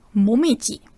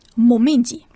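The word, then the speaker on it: Momiji (もみじ): Da gibt es zwei Aussprachen. Die japanische Lesung, das ist Momiji. Momiji. Oder die chinesische Lesung Koyo. Koyo.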